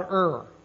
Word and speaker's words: Ur: Abraham's living in this little town called Ur. That's the name of the town. It's spelled U-R. Not Irville, just Ur.